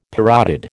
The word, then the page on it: parotid: American English